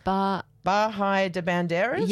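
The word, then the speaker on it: Bahía de Banderas: Is it Bahai de Banderas? – Yes, you said it right.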